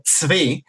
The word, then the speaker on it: Tzvi: Tzvi, que son tres letras en hebreo, simplemente Tzadik Bet Yud, se pronuncia Tzvi, y lamentablemente a mucha gente le cuesta em, entender el nombre, porque no es un nombre que existe en español.